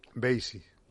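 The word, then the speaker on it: Bassey: Basie. He dicho Basie porque se lo he oído alguna vez. Se lo he oído antes. – Basie, que probablemente la S es sonora. – Es sonora. – Basie.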